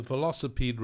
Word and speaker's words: Velocipede: So tell me about the Velocipede Races. Am I saying it right? – Yes, yes.